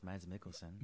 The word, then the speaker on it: Mads Mikkelsen: Am I saying that right? – That's how I say it, Mads Mikkelsen.